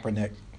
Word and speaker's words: Kaepernick: Yes, Kaepernick.